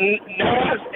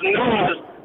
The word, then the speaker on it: Nas: It's not Nas, it's pronounced Nas, and it comes from a king named King Nazir.